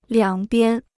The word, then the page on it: 两边: liǎng bian